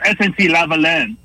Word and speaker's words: SNC-Lavalin: You're talking about SNC-Lavalin. – Yeah. – That's how it's said.